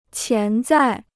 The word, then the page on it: 潜在: qián zài